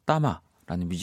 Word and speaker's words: Thama: T H A M A 이렇게 쓰고 따마라고 읽는 뮤지션의 싱 잇이라는 노래였습니다.